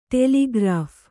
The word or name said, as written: ಟೆಲಿಗ್ರಾಫ್